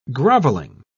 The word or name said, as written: groveling